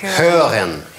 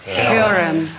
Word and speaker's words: hören: А читается он «hören». – «Hören». – Да, когда над «о» две точки, это называется по-немецки «umlaut», значит, читается не «о», а «ö». «Hören».